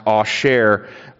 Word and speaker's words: Asher: So she names the son Asher, which is a transliteration of Aser, A-S-E-R, pronounced Asher, which means happy.